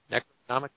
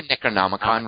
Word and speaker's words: Necronomicon: Like that Necromon- yeah. Necromonicon. How do you say that? Necronomicon. – It's a it's the Necronomicon ah.